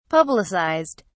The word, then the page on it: publicized: British English